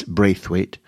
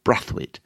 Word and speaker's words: Braithwaite: Yes, I know to you and I it reads Braithwaite, but he has parentage from Guyana, and therefore the pronunciation is Brathwaite.